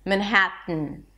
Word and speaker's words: Manhattan: Manhattan, Manhattan. However, you'll notice that a lot of people pronounce it slightly different. And that is because they're not even bringing the tip of the tongue up here. They block the air in the throat. It's called a glottal stop.